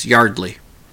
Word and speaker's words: Yeardley: It may be spelled Yeardley, but it's Yardley.